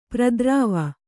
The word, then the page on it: ಪ್ರತಿರವ: prati rava